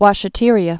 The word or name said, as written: washeteria